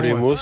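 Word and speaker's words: Ego Lemos: Egolemos. – Oui, c'est comme ça qu'on prononce.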